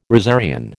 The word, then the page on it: rosarian: American English